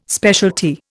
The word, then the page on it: specialty: Indian